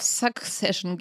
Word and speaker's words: Succession: So, also, wir kommen zu Succession. Jetzt ist mein erstes Problem schon mal. Kannst du es bitte nochmal für mich aussprechen? – Na, ich würde eher so auf Succession gehen, ja.